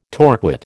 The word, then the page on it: torquate: American English